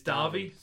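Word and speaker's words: Davi: Robert, is it Darby or Davy? It's Darby. Right, yeah. It's spelled obviously Davy, but it's Darby.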